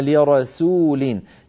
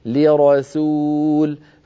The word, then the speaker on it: لرسول: لما قلت ألا نؤمن لرسول، لرسو، لابد من أن بعد أن فخمنا الراء لرى أن نعود فنرقق السين فنقول لرسول، فإن تركنا الفم على هواه فإنه أسهل على الفم أن ينطق السين مفخمة بعد أن نطق الراء قبلها مفخمة، فيقول القائل لرسول، لرسول يأتي، فتأتي إما صادا أو قريبة من الصاد، إما سينا مفخمة قليلا أو صادا، فلننتبه إلى هذا، والباقي كان جيد.